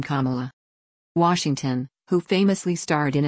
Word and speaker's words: Kamala: Scandal star Kerry Washington teaches DNC crowd how to pronounce Kamala, like a comma in a sentence.